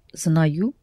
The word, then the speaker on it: знаю: Знаю. – Accented on A. – Sorry. Знаю.